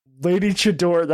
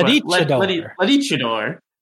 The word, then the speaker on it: Lady Chador: Lady Chador. That's not how I read it, but you are correct in how it should be pronounced. – Yeah, like a luchador. Lady Chador.